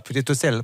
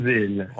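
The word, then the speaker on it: Pietrosella: Alors, qu'est-ce que vous avez vu là-bas, Pietrosel? Parce qu'on ne prononce pas le A, normalement, on est bien d'accord? – Et oui, c'est dans l'accent. Pietrosel.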